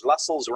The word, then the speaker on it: Lassells: Lassells rhymes with tassels and is in Wetherby.